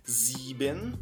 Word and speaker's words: sieben: O sete se escreve sieben, sieben, mas você vai pronunciar sieben, sieben. Você vai travar o lábio e vai dar uma engolida no E. Sieben.